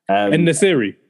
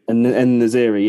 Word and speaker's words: En-Nesyri: En-Nesyri. – En-Nesyri, yeah.